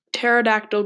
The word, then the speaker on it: pterodactyl: Why can't you hear a pterodactyl going to the restroom? Because the P is silent.